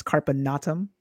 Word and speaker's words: Carpe Natem: So, Carpe Natem, um, or as your husband properly pronounces it. It's carpinatum, but because yeah.